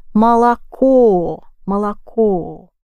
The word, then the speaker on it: молоко: Three о letters and the last one is accented. Молоко, молоко.